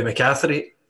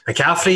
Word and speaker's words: McCaffrey: I am your host, Louis McCaffrey. Oh my God, I pronounced my own name wrong. McCaffrey, even.